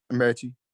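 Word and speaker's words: Metchie: How do you say Michie or Michie? – Michie.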